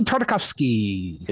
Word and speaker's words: Tartakovsky: How do you say his last name again? – Gendy Tartakovsky. – There you go. You got to say it with the accent.